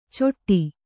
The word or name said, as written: ಚೊಟ್ಟಿ